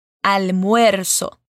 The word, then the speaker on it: almuerzo: Almuerzo. Bom, essa é uma palavrinha que alguns alunos sentem um pouquinho de dificuldade de pronunciar. Vamos por partes então. Al. Muer-so. Juntando tudo agora, almuerzo.